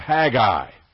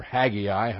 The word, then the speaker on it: Haggai: the book of Haggai, or Haggai, however you choose to pronounce it.